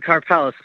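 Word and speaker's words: Karpelès: And I want your opinion about Bitcoin, because I noticed you went after a guy named Kaplis. Is that right? Mark Kaplis? Or is it pronounced Karpelis? – We always said Karpelis.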